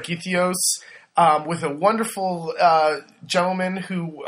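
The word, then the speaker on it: Gytheio: So, in this little fishing village of Githios? – Yes. – Did I say that right? Uh-huh. Or Githio? – Either way, I think.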